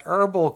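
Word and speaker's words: Herbl: and Robin Big fame back in the day on MTV, have partnered up with the company Herbal, H-E-R-B-L. I'm pretty Herbal. – Sure they pronounce, yeah. Herbal.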